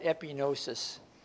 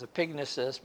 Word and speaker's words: epignosis: The word lust there is, I always pronounce that epinosis. The correct pronunciation is epignosis, but I didn't like that word.